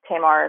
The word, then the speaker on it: Tamar: So my name, T-A-M-A-R, like Tamara without the A, or Tamar if you're a fan of Tamar Braxton, but it's pronounced Tamar, like tomorrow. Wright, like a writer, W-R-I-T-E-S.